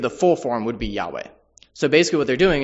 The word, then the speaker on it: Yahweh: The current pronunciation, if you're curious, Yahweh, comes from the fact that the shortened form that is seen at times in the Hebrew Bible is just the first part, the YH, pronounced as Yah.